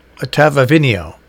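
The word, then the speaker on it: Ottavino: No, it's Ottaviani.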